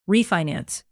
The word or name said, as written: refinance